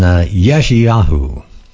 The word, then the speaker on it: Yeshayahu: Then we turn over to, turn back to Isaiah, or how do you say Isaiah? Isaiah in Hebrew. You say, it's written Yeshayahu is actually how we should pronounce it.